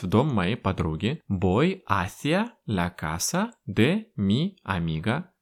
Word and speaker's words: hacia: Hacia. Пишется он через букву H, которую мы не читаем. Hacia. Ударение на первый слог.